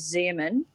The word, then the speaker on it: Xeomin: There's Botox, Dysport, and the newcomer is Xeomin, which is spelled with an X.